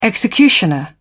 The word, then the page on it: executioner: British English